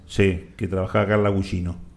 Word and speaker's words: Gugino: No, no, pero se pronuncia así. – Gugino.